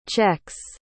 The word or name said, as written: czechs